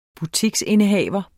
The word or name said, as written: butiksindehaver